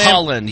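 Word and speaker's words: Haaland: Is that how you say his name? – Holland, yes.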